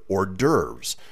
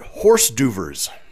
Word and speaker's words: hors d'oeuvres: It's H-O-R-S space D apostrophe O-E-U-V-R-E-S. Too many damn vowels. – Yeah, hors d'oeuvres. – And they pronounced it horse d'oeuvres.